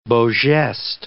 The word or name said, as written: beau gestes